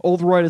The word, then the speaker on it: Oldroyd: That's one of those names where you don't know, you know, if there's like a silent letter somewhere or like if you extend one of the vowels too long. I don't know. So Oldroyd is how you say it.